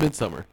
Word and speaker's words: Midsommar: No, it's just pronounced Midsommar.